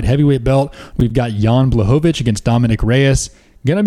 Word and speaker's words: Blachowicz: Blachowicz.